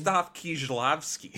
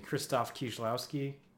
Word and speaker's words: Krzysztof Kieślowski: It's Krzysztof Kieślowski. – Excuse me, Krzysztof Kieślowski.